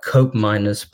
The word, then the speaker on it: Koopmeiners: he's given quite a big responsibility for ball progression in the team, because he plays closely in partnership with Tern Koopmeiners. I've just butchered his name there. It's more like Koopmeiners, but I can never say it without reminding myself.